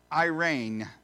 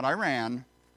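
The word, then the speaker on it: eirene: It is pronounced Irene, not Iran.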